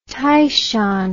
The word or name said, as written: Taishan